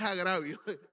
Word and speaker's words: agravio: Bueno, no tiene acento en la I. Es agravio.